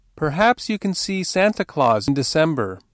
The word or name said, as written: December